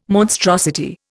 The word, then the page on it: monstrosity: Indian